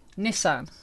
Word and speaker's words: Nissan: Nissan.